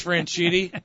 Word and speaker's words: Franchitti: how do you pronounce the last name, Franchitti? – I think it's Judd. – I think it's Franchitti.